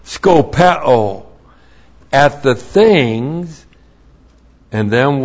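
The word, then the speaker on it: Skopeo (σκοπέω): And then we have skopeo. S-K-O-P-E, long O. Skopeo.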